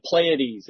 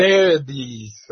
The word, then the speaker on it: Pleiades: The Pleiades or the Phryades or Frailties? No? – No, it's the Pleiades. It's a concept. – Pleiades, right.